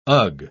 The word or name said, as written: ugh